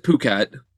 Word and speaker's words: Phuket: They pronounce it Phuket, Yeah, Phuket.